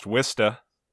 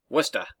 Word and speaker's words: Worcester: Like, over here we have, it's spelt Worcester, but it's pronounced Wista. – Wista.